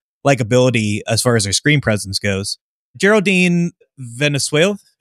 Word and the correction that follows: Viswanathan: is that how you pronounce it? – Geraldine Viswanathan. – Viswanathan, yeah.